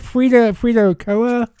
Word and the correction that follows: Kahlo: Frida Kahlo. – Kahlo.